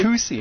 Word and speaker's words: Tucson: Tucson.